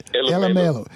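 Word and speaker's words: Elumelo: Well, African billionaires like Aliko Dangote and Tony Ehelo, correct me if I'm pronouncing his name wrong, because I know you know all of these. – Elamelo.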